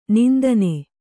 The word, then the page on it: ನಂದನೆ: nandane; kannada